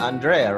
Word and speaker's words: Andrea: So much, Alison and Andrea, right? – Yeah.